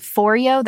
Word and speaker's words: Foreo: I think it's called Foreo. That's how you pronounce it.